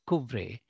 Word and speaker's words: Coupvray: It's the French name, C-O-U-P, P as in Peter, V as in Victor, R-A-Y. Once again, C-O-U-P, V-R-A-Y, it is pronounced Couvray.